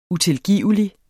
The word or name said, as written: utilgivelig